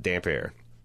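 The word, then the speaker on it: Damphair: But to me, it just read like this guy has got damp hair. He's this saltwater priest and he's just always in the surf drowning people. So he's got damp hair. But that's how you pronounce it, Dampier.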